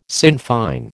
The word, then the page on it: Sinn Fein: American English